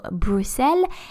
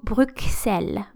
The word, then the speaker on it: Bruxelles: Uh, because of this X, actually there are um, kind of two different pronunciations, so Bruxelles and also Bruxelles, Bruxelles,